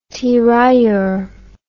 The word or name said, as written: tirailleur